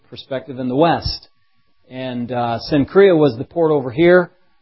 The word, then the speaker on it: Cenchrea: It says that she's a servant of the church in Cenchrea. That's how you pronounce that, at least the best way we can figure in English for that when you're reading it.